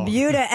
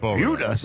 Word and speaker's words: Buda: Buda. – It's spelled B-U-D-A, and I looked it up. It is pronounced like beauty.